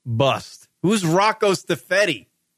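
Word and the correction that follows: Siffredi: Stifredi. I don't know how do you pronounce that last name? Siffreddi.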